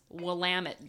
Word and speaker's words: Willamette: They told me it's Willamette, damn it.